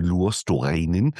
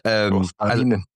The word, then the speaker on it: Luostarinen: Und ich meine, das wäre, finde ich, ein Riesenproblem, weil dann würdest du ja diese überragende dritte Reihe mit Lundell, Marchand und Luostarinen, ich habe noch nicht richtig ausgesprochen. – Luostarinen. – Ja, Stimmt.